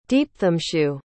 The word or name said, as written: deepthamshu